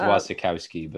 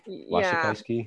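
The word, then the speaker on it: Wasikowska: Um, Mia Wachowski, and I forgot to pull. – I think it's Wasikowski, but Wasikowski.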